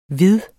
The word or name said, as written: hvid